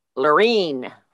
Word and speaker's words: Lorene: Lorene. Rhymes with terrine.